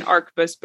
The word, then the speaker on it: arquebus: then chances are the H is silent. Sorry, very long-winded answer. – Well, I'll use the professional version then and call it an arquebus both ways.